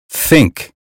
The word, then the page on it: think: American English